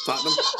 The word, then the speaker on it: Tottenham: Like when you told me it's not Tottenham, it's all one word. Tottenham? – You got one too many syllables in there, bro.